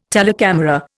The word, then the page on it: telecamera: Indian